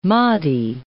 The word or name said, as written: Mahdi